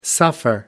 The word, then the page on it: suffer: American English